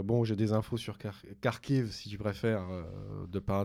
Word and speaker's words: Kharkiv: après, pendant que l'autre partie, à mon avis, là où ils ont eu la problématique, c'est euh, Kharkov. Bien prononcé. Kharkiv. Oui, moi je me prononce en russe, ah.